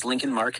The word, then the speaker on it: Lincoln Mark: My name's Lincoln Mark.